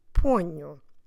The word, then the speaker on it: ponho: P-O-N-H-O. Ponho. And we have this nasal consonant sound nu because of the fact that we have the NH together. N-H, new. Also, that nasal sound, that nasal consonant sound, let's say it like that, also makes the first O slightly nasal too. So we start with pon, already nasal, poño, Don't forget to stress the second to last syllable in this case, the first one of the word.